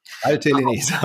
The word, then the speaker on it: Valtellinese: Valtellinese, okay.